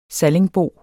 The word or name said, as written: sallingbo